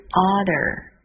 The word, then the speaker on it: order: Order 这个词是点餐的意思 O-R-D-E-R, Order, O 二的发音是 or, D E R, der, order.